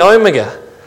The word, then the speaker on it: Omega: He was and is the Alpha and the Omega. And yes, that is how it's pronounced. Don't trust the watch company.